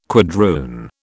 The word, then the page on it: quadroon: British English